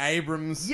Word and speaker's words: Abrams: Abrams, I've said it wrong again. Sorry. Sorry, geek dudes. J.J. Yes. Abrams. – Yes. – Abrams. I do learn.